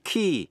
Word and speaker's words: quay: A-C-C-U-R-A-C-Y, accuracy. Và precision, P-R-E-C-I-S-I-O-N. Cũng ôn lại những chữ dễ phát âm sai như là chữ key, Q-U-A-Y, nghĩa là bến tàu. Debt, D-E-B-T, nghĩa là nợ. Và receipt, R-E-C-E-I-P-T, là biên lai.